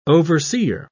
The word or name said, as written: overseer